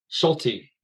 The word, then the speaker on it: Schulte: Schulte, yes.